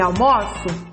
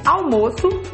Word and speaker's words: almoço: Mas olha a pronúncia, por isso que são homônimos homógrafos. São só na grafia iguais. Porque o tom, o som vai ser diferente. Almoço, substantivo. Almoço, o verbo.